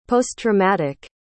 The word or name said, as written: posttraumatic